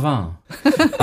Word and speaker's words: Vin: Und mein Tipp wäre da das Fin Vin. Habe ich das schon wieder falsch ausgesprochen? – Vin. – Also mein Tipp wäre dann Vin.